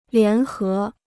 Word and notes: 联合: lián hé

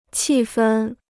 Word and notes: 气氛: qì fēn

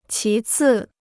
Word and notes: 其次: qí cì

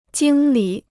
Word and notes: 经理: jīng lǐ

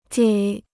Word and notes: 给: gěi

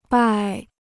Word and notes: 败: bài

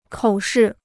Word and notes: 口试: kǒu shì

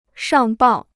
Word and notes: 上报: shàng bào

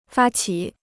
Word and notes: 发起: fā qǐ

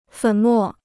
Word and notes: 粉末: fěn mò

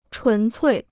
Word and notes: 纯粹: chún cuì

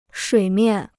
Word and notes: 水面: shuǐ miàn

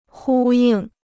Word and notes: 呼应: hū yìng

